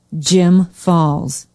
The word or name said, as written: jim falls